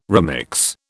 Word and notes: remix: British English

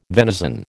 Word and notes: venison: American English

